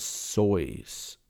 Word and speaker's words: Seuss: His name, Seuss, was initially pronounced as Sois. Sois. S-O-I-C-E.